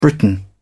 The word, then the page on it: briton: British English